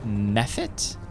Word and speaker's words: mephit: It's an ice mephit, M-E-P-H-I-T. – Yeah. Mephit, mm-hmm. Mephit. Yeah, I'd say that's right. Mephit.